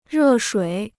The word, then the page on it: 热水: rè shuǐ